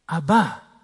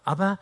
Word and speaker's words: Abba: Abba. Betonung auf der zweiten Silbe. Es gibt auch Abba, aber das ist eine schwedische Popgruppe.